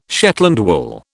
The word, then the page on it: Shetland wool: British English